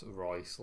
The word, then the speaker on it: Reuss: If you have any issues with my report, if I've been pronouncing Royce wrong or Roos, I can't remember. – How's it spelled, like Rolls Royce? – No, R-E-U-S-S. – That's Royce, like Royce.